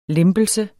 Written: LEMPELSERNES

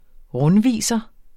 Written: rundviser

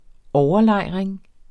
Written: overlejring

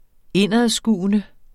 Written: indadskuende

